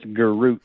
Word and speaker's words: Garroutte: It's Garut.